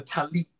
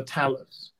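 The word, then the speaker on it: tallit: We said a talit, not a talus.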